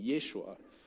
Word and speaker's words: Yeshua: In the original languages, and the phonetically, it would be, you would pronounce it Yeshua, which is very close to and pretty much the same thing as Joshua back in the Old Testament, but it means he who saves.